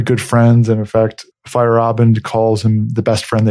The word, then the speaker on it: Feyerabend: I said Feyerabend? – It's Feyerabend.